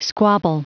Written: squabble

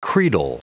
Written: credal

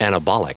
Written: anabolic

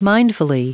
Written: mindfully

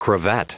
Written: cravat

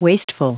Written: wasteful

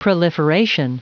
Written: proliferation

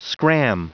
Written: scram

